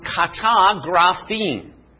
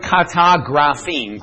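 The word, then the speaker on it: katagraphein: This word is pronounced kata graphene. Now, right is grapho. This word, kata graphene.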